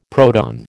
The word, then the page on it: proton: American English